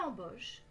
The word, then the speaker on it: embauche: Une embauche est un nom féminin qui veut dire hiring. On l'utilise surtout dans l'expression un processus d'embauche, a hiring process. Pensez à bien prononcer la nasale en au début. Embauche.